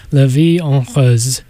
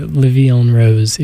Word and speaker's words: La Vie en Rose: there was Edith Piaf with her song La Vie en Rose. Or Le Vie en Rose, if you want the classic English pronunciation.